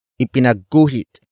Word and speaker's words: ipinagguhit: Word for word it's, ipinagguhit ko ang guro ng mansanas. Ipinagguhit ko ang guro ng mansanas. Ipinagguhit ko ang guro ng mansanas.